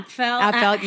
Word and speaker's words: Apfel: I'm going to pronounce her last name wrong, but Iris Apfel. Do you know who I mean? – Apfel.